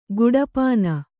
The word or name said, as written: ಕೊಡಪಾನೆ